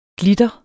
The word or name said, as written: glitter